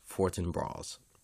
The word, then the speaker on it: Fortinbras: I think it is Fortinbras. Fortinbras. As weird as that sounds to me, I think that is how you say it.